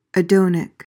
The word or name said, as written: adonic